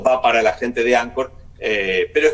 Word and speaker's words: Anchor: Anchor, sí, bueno, Anchor se escribe Anchor con CH, Anchor en inglés.